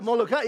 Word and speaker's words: Molokai: And I want to try to see if I can pronounce it right. Molokai? Yeah, Molokai.